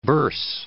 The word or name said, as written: burse